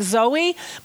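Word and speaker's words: zoe (ζωή): I know it looks like Zoe, but it's pronounced zoe.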